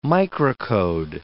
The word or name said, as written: microcode